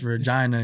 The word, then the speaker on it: Regina: They get mad if you say it like Regina. So you got to pronounce the I, it's Regina.